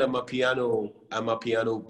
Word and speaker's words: Amapiano: It's called Amapiano, right? Like the South Yeah, Amapiano, South yes.